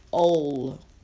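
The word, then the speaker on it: ol (עול): I looked up yoke, and it is ol, as in O-L, kind of, sort of.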